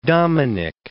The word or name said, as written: Dominic